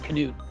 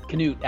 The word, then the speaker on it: Knuth: Like, Knuth, the K is silent. – Yeah, it's Knuth. Knuth actually is how Knuth. We pronounce it. Knuth. – I'm sorry. I apologize. I've been fucking up for years. – The H is silent.